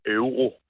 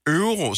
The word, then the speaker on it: euro: Hvordan udtaler du den europæiske valuta? – Det hedder euro. – Euro, så ikke euro, men euro. – Ja, det er en euro.